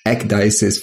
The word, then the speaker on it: Ecdysis: Today, we have back Dr. Jonathan Lundgren, the founder of Blue Dashers Farms, and I'm going to butcher this foundation name, Ecdysis Foundation.